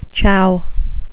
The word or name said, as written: chow